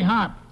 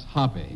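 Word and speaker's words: Hoppe: Who's Willie Hopp? – National billiard champion, and it's pronounced Hoppy.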